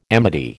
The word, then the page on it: amity: American English